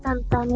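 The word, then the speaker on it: Tintin: Sí, o sea, se escribe Tintín, pero se pronuncia Tantan.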